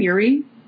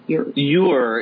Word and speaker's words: Ure: how do we say her last name? Uri? No. – Ure is what they say on the Ask.